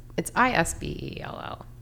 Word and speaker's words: Isbell: It's Isbell.